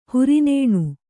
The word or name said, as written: ಹುರಿನೇಣು